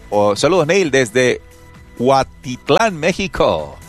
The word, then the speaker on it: Cuautitlán: Ya lo dije bien, no se va a burlar de mí. No lo dije mal. Cuautitlán, México. Lo que pasa es que se ríen de mí por allá porque no sé mencionar el náhuatl, que es el idioma original de los mexicas.